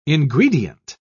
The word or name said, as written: ingredient